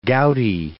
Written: gouty

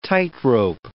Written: tightrope

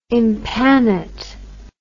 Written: impanate

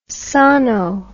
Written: sono

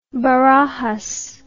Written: barajas

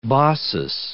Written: Baucis

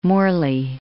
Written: Morley

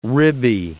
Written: ribby

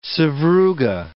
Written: sevruga

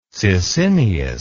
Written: Sisinnius

